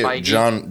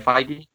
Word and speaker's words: Feige: And then I would say Feige.